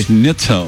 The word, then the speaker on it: Schnitzel: Schnitzel.